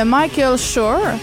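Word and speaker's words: Michael Schur: Donc, The Good Place, c'est une série de Michael Shore. – Ouais. – Est-ce que c'est bien prononcé, ça? Oui, oui. C'est bon.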